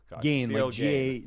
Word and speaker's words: Gane: Cyril Gane? – It's Cyril Gane. – Gane, G-A-I-N. – That's perfect. – How you pronounce it.